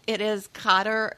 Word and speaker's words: Qatar: But, you know, it is Qatar.